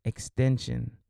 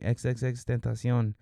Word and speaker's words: XXXTentacion: And a lot of these rappers out here on social media are calling this kid Extension. They're not even saying his name right. XXXTentacion.